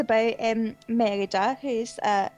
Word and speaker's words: Merida: Yeah, well, I always called it Merida, but John Lasseter calls it Merida, so we'll go with the God's version. Merida, yep.